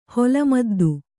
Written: ಹೊಲಮದ್ದು

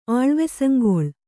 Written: ಆಳ್ವೆಸಂಗೊಳ್

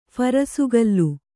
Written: ಫರಸುಗಲ್ಲು

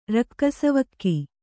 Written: ರಕ್ಕಸವಗೆ

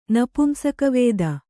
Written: ನಪುಂಸಕವೇದ

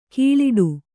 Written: ಘೀಳಿಡು

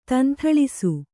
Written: ದೊಂದುಳಿಸು

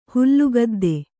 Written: ಹುಲ್ಲುಗದ್ದೆ

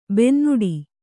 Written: ಬೆನ್ನೋಡು

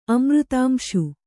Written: ಅಮೃತಾಂಶು

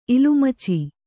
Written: ಇಲಿಮಿಚ್ಚಿ

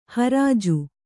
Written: ಹುರ್ಜಿ

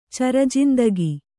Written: ಚರಜಿಂದಿಗಿ

